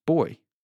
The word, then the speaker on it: buoy: Well, this week I learned that the word buoy is pronounced boy.